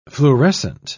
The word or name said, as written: fluorescent